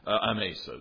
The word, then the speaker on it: Amasa: So he puts Amasa. I can't help but pronounce that the wrong way. We have a neighbor who's named, and they pronounce it Amasa. I've heard it for decades.